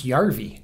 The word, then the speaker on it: Puljujärvi: There, there's no hard J in Finnish. – Yeah. – Is there any... Yeah. Yeah, because it's Järvi.